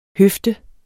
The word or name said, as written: høfde